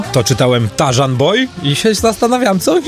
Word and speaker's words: Tarzan: To Tarzan, że niby RZ, ale nie czyta się jako Ży, tylko Rzy.